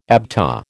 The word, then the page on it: ABTA: American English